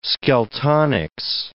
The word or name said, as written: Skeltonics